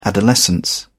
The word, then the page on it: adolescence: British English